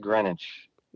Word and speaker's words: Greenwich: And I hate to be this guy, but it's actually pronounced Greenwich.